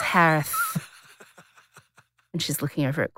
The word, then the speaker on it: Herath: Last name is pronounced like Harris with a lisp. It's Harith. H-E-R-A-T-H.